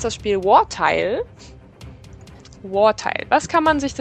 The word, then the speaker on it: Wartile: Es klingt ja schon so, Wartile. – So muss man es auch aussprechen, Wartile. – Wartile.